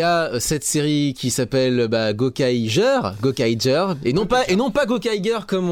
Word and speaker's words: Gokaiger: Si vous lisez les katakana, ça s'écrit Ja, ça s'écrit pas Gar. Et en plus, c'est des Rangers, c'est pas des Rangers. Donc, c'est Gokaiger.